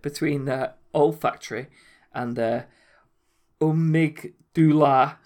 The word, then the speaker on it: amygdala: The amygdala.